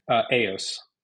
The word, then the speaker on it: AIOS: And is it pronounced AIOS? – AOS. – AOS, that's what I thought.